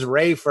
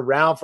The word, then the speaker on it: Ralph: Inner monologue, I'm Ralph Fiennes.